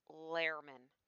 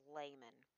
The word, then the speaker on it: Lehrman: The article is The Political Speechwriter's Life, and it's written by Robert Lehrman. I pronounced it a few times as layman.